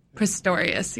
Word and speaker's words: Pistorius: Pistorius? – Pistorius, yeah.